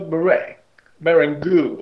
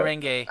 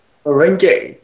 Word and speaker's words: merengue: They could use it, but instead they prefer to watch celebrities dance a poor version of merengue, or whatever. – Merengue. – How they pronounce it. Merengue. Thank you.